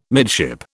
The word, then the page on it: midship: British English